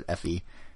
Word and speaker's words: Fe: Fee. I still don't know how to pronounce it. Fe. – Yeah, I would say Fe.